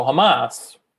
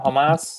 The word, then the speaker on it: Hamas: Yeah, so Hamas, am I saying that right now? Hamas? – Yes, that's correct.